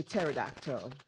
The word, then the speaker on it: pterodactyl: Someone was saying, they were talking about pterodactyl, another word where the P is silent. We will say pterodactyl. – Pterodactyl. Yeah, because pterodactyl actually is spelled with a P.